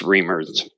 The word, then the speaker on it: Reimer: It's spelled Reimer, but it's Reimer.